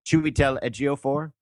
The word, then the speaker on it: Chiwetel Ejiofor: How do you pronounce that? – Chiwetel Ejiofor. – Okay, thank you.